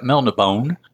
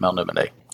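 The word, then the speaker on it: Melniboné: For my whole life, I've pronounced that Melnibone because I've never heard anybody pronounce it. And so I'll take Stephen's call that it is called Melnibone, or pronounced Melnibone.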